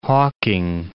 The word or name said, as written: hawking